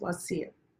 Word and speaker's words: Wasi: But when I listen to the true Arabic pronunciation, it's Wasi. It's like a little at the end.